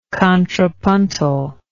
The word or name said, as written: contrapuntally